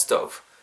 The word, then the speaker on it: stove: 그래서 the gas stove, the gas stove stove 발음하실 때도 S-T-O-V-E V 사운드 똑같이 again rabbit teeth 그렇죠?